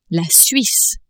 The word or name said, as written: Suisse